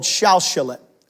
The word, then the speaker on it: Shalshalet: It seems to be only found four places in the Torah, and it's called Shalshalet. It's how we would pronounce it.